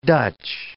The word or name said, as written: Dutch